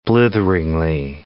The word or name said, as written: blitheringly